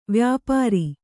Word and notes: ವ್ಯಾಪಾರಿ: vyāpāri

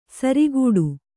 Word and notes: ಸಾರಗೆಡು: Kannada

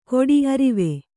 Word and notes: ಕೊಡಿ ಅರಿವೆ: kannada

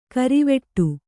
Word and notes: ಗರ್ವಾಟ: garvāṭa

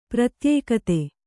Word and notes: ಪ್ರತ್ಯುಕ್ತಿ: kannada

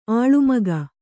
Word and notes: ಅಳುಮುಖ: aḷumukha

